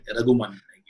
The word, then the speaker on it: Rahman: I said, sir, you know, Rahman ko Raghuman bolte na. So I asked Rahman, yeah, here their pronunciation is like Raghuman.